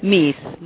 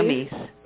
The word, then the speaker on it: Mies: Mies, Maria Mies.